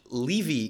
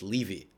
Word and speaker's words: Levy: And I also had to deal with, in English, Levy is a last name. So my name is a last name and it's pronounced Levy. I don't know why.